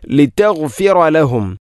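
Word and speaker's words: ലിതഗ്ഫിറ ലഹും (لِتَغْفِرَ لَهُمْ): ലിതഗ്ഫിറ ലഹും, ലിതഗ്ഫിറ ലഹും, ഇവിടെ ലിതഗ് ഗൈന് പകരം ലിതഗ്ഫിറ ലഹും. ഇത് വളരെയധികം ഗൗരവത്തിൽ ശ്രദ്ധിക്കേണ്ട ഒരു കാര്യമാണ്. പലപ്പോഴും നമ്മളങ്ങനെ പറയാറുണ്ട് ലിതഗ്ഫിറ ലഹും. അപ്പോൾ ലിതഗ് ലിതഗ്ഫിറ ലഹും, ഗൈനിൻ്റെ ഉച്ചാരണം അല്ലെങ്കിൽ അതിൻ്റെ മഖ്റജ് തൊണ്ടയുടെ ഏറ്റവും മുകൾ ഭാഗമാണ്, അത് പ്രത്യേകിച്ചും ശ്രദ്ധിക്കണം. ലിതഗ്ഫിറ ലഹും ജഅലു അസ്വാബിഅഹും ജഅലു, ഇവിടെയും ഐനിൻ്റെ ഉച്ചാരണം ശ്രദ്ധിക്കേണ്ടതാണ്.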